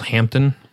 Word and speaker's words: Hampden: It's to me, the best Jamaican distillery is one called Hampton, and it's spelled Hampden,